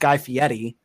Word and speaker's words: Guy Fieri: I like the Guy Fieri one too, where apparently it's pronounced Guy Fieri. Didn't know that.